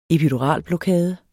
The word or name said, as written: EPIDURALBLOKADER